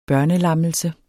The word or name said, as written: børnelammelse